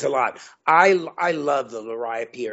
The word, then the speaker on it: liriope: And now I say liriope. Some people say lirio.